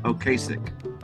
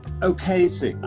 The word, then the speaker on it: Ocasek: It was oddly produced by Rick Ocasek. Is that how you say his name? – I would say Ocasek. – Ocasek, I think that's right. I think you're right, yes.